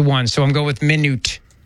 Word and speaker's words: Minot: Because I was just waiting to hear one pronunciation. It's Minot.